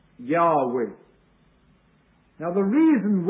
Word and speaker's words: Yahweh: In the Hebrew it appears as J-H-W-H. And the proper pronunciation is probably Yahweh. Yahweh. In fact, the pronunciation Jehovah did not occur until the year 1518, when it occurred in a Roman Catholic book, just a year after Luther had nailed his theses in Wittenberg.